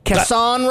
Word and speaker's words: Casson Road: Casson Road? – I like to think of it like it's Garcon from... – Casson. – From Beauty and the Beast.